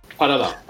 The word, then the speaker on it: Farala: ben e, yani istediğim değil yani öngördüğüm e, Ugasio, e, Drag Vulcano ve Carmen Ferala diyorum. Parala. – Ay özür dilerim. – Otoy adını telaffuzundan çok şeydi. Parala. – Okey. Ben ikinizin arasında bir yerdeyim. Carmen Farala, Ugacio ve Sagitario diyorum ben de.